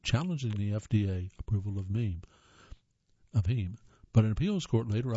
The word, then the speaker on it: heme: Number 11, Impossible Foods spent years developing a molecule called heme, which, I'm sorry, that's pronounced heme. It's H-E-M-E, but it's pronounced heme. I guess it's like the things on computers called memes, which gives its product a bloody red appearance and beefy taste.